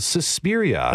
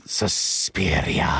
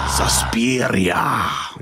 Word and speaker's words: Suspiria: Called Suspiria. – That's not how you say it. – Suspiria. – Suspiria.